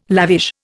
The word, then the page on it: lavish: Indian